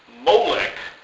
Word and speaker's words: Molech: They come along and they call him Molech, which comes, this is how they do this, they took the vowels from another word, Bosheth, which means shame.